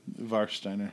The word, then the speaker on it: Warsteiner: Warsteiner. – Okay, great. – Warsteiner. The W's a V, thanks, bro.